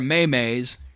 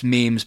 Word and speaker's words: memes: It's like, I love, you know, the effort these people are going into with these good-ass memes, you know, or maymays. You know, I know it's pronounced memes, people, believe me, I don't want to end up getting, like, stupid tweets.